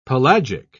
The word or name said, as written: pelagic